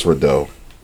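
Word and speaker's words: Rideaux: Because it's R I D E A U X. That's Rado. – yeah, French. usually Rado. Bordeaux. – Uh, uh, Nipsey Hussle, Bino Rado, Dave East, Clarity.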